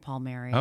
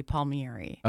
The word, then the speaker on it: Palmieri: So it's Palmieri, it's not Palmieri? – That uh, it is. My family pronounces it Palmieri. – Okay. Although the correct, the Italian way would be Palmieri. Okay. It, me, me, airy.